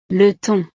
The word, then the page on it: le thon: frances